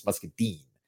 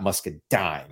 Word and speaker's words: Muscadine: And other people have also reached out to me to be like, dude, you mispronounced Muscadine. It's Muscadine, not Muscadine.